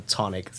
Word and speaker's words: Chthonic: Uh, Chthonic. So the ch I think is just silent.